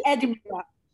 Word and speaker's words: Edinburgh: Scherzo, chi ha seguito la diretta Instagram ha scoperto che mi sono esercitata a pronunciare il nome del duca come lo pronunciano gli inglesi. Duke of Esatto. – Edinburgh. – Sei stata promossa. Come Canberra, è un po' come Canberra.